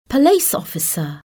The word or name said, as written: police officer